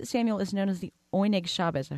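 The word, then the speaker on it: Oyneg Shabes: I hope I'm saying that correctly. – Yep. – Can you talk about the significance of the name? – Yeah, it was a code name. Oinig Shabbos in Yiddish means joy of the Sabbath.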